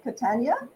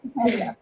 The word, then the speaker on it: Catania: Second guest is a dear friend of the first guest, Sadie, and I'm going to pronounce it Catania. – That's correct, yes. – Catania.